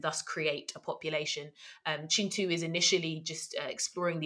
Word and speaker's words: Kintu: Um, the idea of the first man and uh, his experience on the earth, and it's called Chintu. Um, that's spelled K I N T U, and um, we pronounce our K's like chuz in Luganda and it centers uh, Chintu and the wife he eventually um, meets and falls in love with.